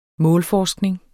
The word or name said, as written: målforskning